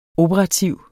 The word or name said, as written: OPERATIVT